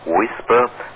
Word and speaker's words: WSPR: Er wird über das Thema WSPR, nämlich das heißt Weak Signal Propagation Reporter, ausgesprochen WSPR, halten.